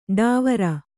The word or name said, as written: ಢಾವರ